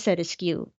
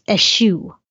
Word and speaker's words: eschew: At FitzJohnP, was pronunciation of eschew askew? – Probably. – Which has made me laugh. Because I think you said askew. – I said askew. And that's the way I pronounce it. Well, the pedantic jerk did reach out to me via text and told me that we were both wrong and that it was eschew. – He is correct.